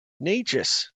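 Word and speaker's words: Nečas: Necas.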